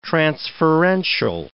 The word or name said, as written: transferential